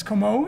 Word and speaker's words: Como: They wrote in and told me how to pronounce his last name, and I believe it is Como is the right way to say that.